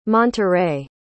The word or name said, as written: monterey